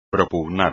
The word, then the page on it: propugnar: espagnol